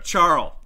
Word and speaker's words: Charl: Uh, Carl Schwartzel. Ch- Charl. – Charl. Okay, like, almost like Charo, but Charl, yeah.